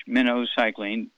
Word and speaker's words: minocycline: It's pronounced like the bait fish, minocycline, minnow, okay?